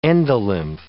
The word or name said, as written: endolymph